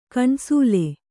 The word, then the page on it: ಕಣ್ಸೋಲ್: kaṇsōl